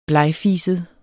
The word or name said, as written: blegfiset